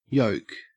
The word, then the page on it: yoke: Australian English